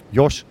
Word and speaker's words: Jos: Yeah, pronounce it like Josh.